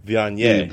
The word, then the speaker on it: Viognier: Via Vigne. – Oh, Viognier.